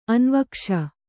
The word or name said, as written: ಅನ್ವೀಕ್ಷೆ